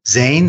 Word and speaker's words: Zayn: and I think it's Jane or One Direction. – Zane. – Is that who it was who left? Zane. Yeah, Zane. That's spelled Z-A-Y-N.